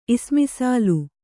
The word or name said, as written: ಇಸ್ಮಿಸಾಲು